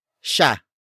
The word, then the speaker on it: siya: The next word is sha. Sha. Sha. Sha is spelled as S-I-Y-A.